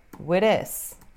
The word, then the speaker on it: with: O último TH de with basicamente some e vira with this. Ok? Ou, numa linguagem super informal, vira with this. Na verdade, tem essas duas opções de pronúncia.